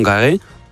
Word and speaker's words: Carré: Entró con una bata blanca, parecida a la que usaban los empleados, caminó hasta el Salón Carré. ¿Lo he dicho bien? ¿Carré? – Yo creo que sí.